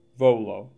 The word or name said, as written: Volo